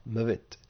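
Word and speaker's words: mavit (מות): Mem vav tav, mavit.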